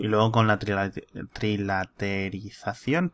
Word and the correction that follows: trilateración: trilateración, ¿cómo se dice? Trilateración, sí.